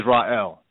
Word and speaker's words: Rael: My name is Ra'el. – spell that, please? – R-A-E-L. – R-A-E-L.